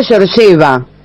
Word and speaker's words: Georgieva: Cristalina Georgieva.